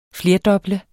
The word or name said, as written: flerdoble